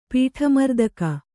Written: ಪೀಠಮರ್ದಕ

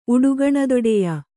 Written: ಉಡುಗಣದೊಡೆಯ

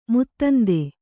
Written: ಮತಾಂಧ

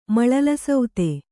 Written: ಮಳಲಸೌತೆ

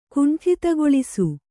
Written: ಕುಂಠಿತಗೊಳಿಸು

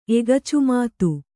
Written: ಎಗಚುಮಾತು

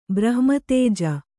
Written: ಬ್ರಹ್ಮತೇಜ

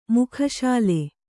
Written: ಮುಖಶಾಲೆ

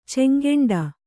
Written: ಚೆಂಗೆಂಡ

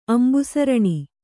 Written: ಅಂಬುಸರಣಿ

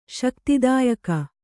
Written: ಶಕ್ತಿದಾಯಕ

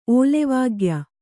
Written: ಓಲೆವಾಗ್ಯ

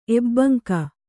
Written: ಎಬ್ಬಂಕ

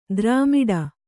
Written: ದ್ರಾಮಿಡಿ

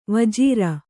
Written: ವಜ್ರಿ

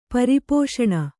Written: ಪರಿಪೋಷಣ

